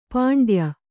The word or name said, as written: ಪಿಂಡಾಯು